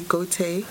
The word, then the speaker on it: Gotye: Well, I'll say Gauté. – Gauté. Well, the next person is Gauthier, I think, G-O-T-Y-E.